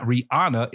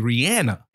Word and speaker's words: Rihanna: It's not Rihanna. It's actually Rihanna.